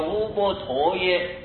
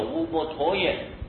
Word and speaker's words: 乌波陀耶: 乌波陀耶啊，那个乌波陀耶就是和尚的正音，印度正确的印度翻音呢、就念作乌波陀耶，乌波陀耶啊。